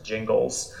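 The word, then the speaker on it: Gingles: I believe it's pronounced Jingles. – Yeah, I was curious to see how you were going to pronounce it. I've heard it like 12 different ways. – I was 10, and so I wasn't there, but I've always said Jingles.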